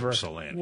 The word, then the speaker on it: Ypsilanti: Give us a call now, just like Jane from Ypsilanti. Good morning, Jane. You're on with the Inside Outside Guys on WJR. – She's going to correct you. It isn't Yip. It's Ypsilanti.